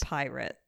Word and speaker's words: PYRT: It's spelled stupidly. It's like PYRT and it's pronounced Pirate.